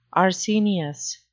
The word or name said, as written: arsenious